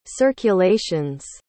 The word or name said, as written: circulations